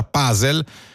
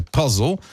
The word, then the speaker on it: puzzle: La pronuncia puzzle, che è quella un po' italianizzata perché in inglese dovrebbe essere puzzle, si diffonde sempre di più perché l'inglese è un po' meno esotico rispetto a una volta, però se dite puzzle sentitevi tranquilli.